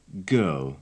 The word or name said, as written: girl